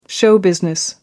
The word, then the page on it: show business: American English